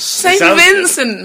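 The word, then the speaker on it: St. Vincent: Saint Vincent. How do you say it? I thought her name was Sam Vincent. – Sam Vincent. – That rhymes. – Saint Vincent, man.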